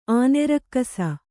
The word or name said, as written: ಆನೆರಕ್ಕಸ